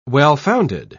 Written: well-founded

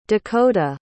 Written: dakoda

